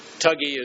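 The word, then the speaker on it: Thuggee: Well, that's the way the Indians pronounce it. It's spelled thug. That's where we get the word thug, is from tug, T-H-U-G. Tuggy is, I think, got an extra G and then an I. Tuggy is the way they pronounce it.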